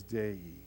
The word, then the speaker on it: dei: The Greek word there is dei. D-E-I is how we would spell it in English.